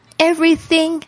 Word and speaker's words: everything: You have everything, 特别注意一下 TH 的发音，两排牙齿要住这个舌尖，轻轻的吐气出来。